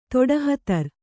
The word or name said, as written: ತೊಡಹತರ್